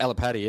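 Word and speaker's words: Alipate: That's how you pronounce it, Alapate? – Alapate, yeah.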